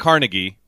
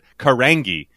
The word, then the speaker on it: Carangi: American supermodel Gia Carnaghi is not Carnegie. If you're from Pennsylvania, you might have heard that. No, it's Car. I'm sorry. I even mispronounced it. Carangi, which sounds like a Star Trek race.